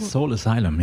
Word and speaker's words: Soul Asylum: Soul Asylum, ja. – So wird so jetzt ausgesprochen.